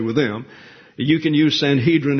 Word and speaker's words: Sanhedrin: The Sanhedrin, when you see the word in the Greek, is Sunedrian.